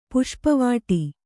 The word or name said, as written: ಪುಷ್ಪವಾಟಿ